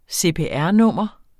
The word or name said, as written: CPR-nummer